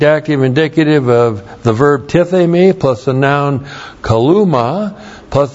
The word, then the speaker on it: tithemi: We have the verb tithemi. Looks like that in the Greek, T-I-T-H, long E-M-I, tithemi.